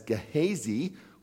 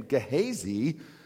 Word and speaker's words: Gehazi: Elisha's assistant, and I'm going to refer to him as Gehazi, which is the Western Pennsylvania pronunciation of that name, Gehazi, says to Elisha, "I know what this woman needs.